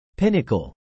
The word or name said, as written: pinnacle